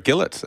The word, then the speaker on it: Gillett: Little mention for a referee who's featured prominently in the Brazzalis is Jared Gillett. – Rhymes with Fillet.